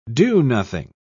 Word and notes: do-nothing: British English

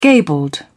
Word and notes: gabled: British English

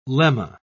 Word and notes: lemma: British English

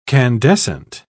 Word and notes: candescent: British English

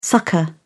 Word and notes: succour: British English

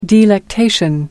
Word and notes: delectation: American English

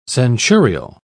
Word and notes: centurial: British English

